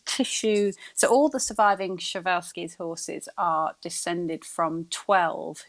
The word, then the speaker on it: Przewalski's: So this is, I had always thought it was Przewalski's. I have been told it is Przewalski's horse. So these are the ones that, although I have actually, I just double checked before we came on here because I wanted to make double sure I was saying it right. And apparently Przewalski's is how some people say it.